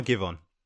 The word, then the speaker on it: Giveon: Is it Giveon or Giveon? – It's Giveon.